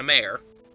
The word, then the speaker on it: mare: Mara here is Mara, M-A-H-R-E-H is how you pronounce it, as in Italian or Latin for ocean, as opposed to female horse, as in a mare. – Right. So it's Mare, not mare.